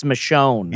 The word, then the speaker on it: Michonne: How do you pronounce the name of the character on The Walking Dead? Michonne? – That's Michonne.